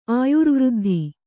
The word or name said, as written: ಆಯುರ್ವೃದ್ಧಿ